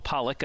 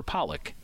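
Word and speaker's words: Pollock: My last name is spelled P-O-L-L-O-C-K. I always put an emphasis on the O-C-K.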